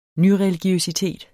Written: nyreligiøsitet

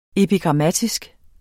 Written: epigrammatisk

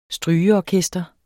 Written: STRYGEORKESTER

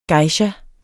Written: GEISHAER

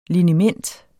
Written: liniment

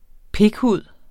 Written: pikhud

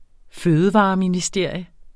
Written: fødevareministerium

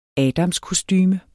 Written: adamskostume

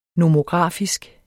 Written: nomografisk

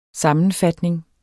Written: SAMMENFATNINGS

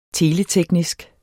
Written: teleteknisk

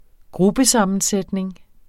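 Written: GRUPPESAMMENSÆTNING

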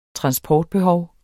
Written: transportbehov

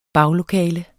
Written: baglokale

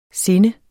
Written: sinde

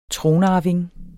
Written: tronarving

